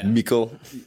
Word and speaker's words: Mikl: But it's spelled like M-I-K-L. – Yeah. – Michael.